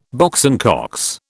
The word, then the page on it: Box and Cox: British English